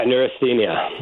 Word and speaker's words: neurasthenia: A word that you use a lot in your book, and correct me if I pronounce it incorrectly, neurasthenia. – Yeah, neurasthenia.